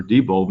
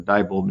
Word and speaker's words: Diebold: These were Diebold machines, or Diebold machines, however you prefer to pronounce it.